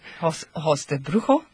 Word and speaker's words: Ojos de Brujo: Ojos de Brujo.